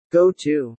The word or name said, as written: go to